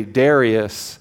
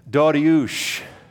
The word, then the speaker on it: Dariush: We say Darius, but the proper way to say his name is Dariush, Dariush Kabir, Darius the Great.